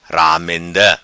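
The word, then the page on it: Ramenda: patua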